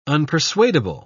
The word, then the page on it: unpersuadable: British English